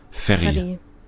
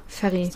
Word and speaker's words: Ferry: Ferry. – Ja, okay. Deutsch ausgesprochen. Weil Ferry wäre da wieder eher so Fee, ne? Aber ich glaube Ferry. – Ja, Ferry.